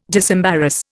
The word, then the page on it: disembarrass: Indian